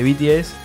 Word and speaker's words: BTS: Con el tema de popularidad, tenemos que nos va a hablar un poquito de BTS, que es una... ¿Lo dije bien? De vuelta, sí, BTS.